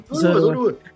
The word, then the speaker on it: Zorua: Como é que pronuncia? Zorua mesmo? – Zorua.